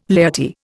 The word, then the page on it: laity: Indian